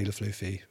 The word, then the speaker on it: Le Flufy: Um, and yeah, it's Paddy Le Flufy.